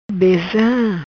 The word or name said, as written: Beza